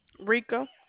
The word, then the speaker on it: Reka: Rika. – So like, can you spell it? – R-E-K-A. – R-E. – R-E. No, R-E-K-A.